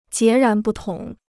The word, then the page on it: 截然不同: jié rán bù tóng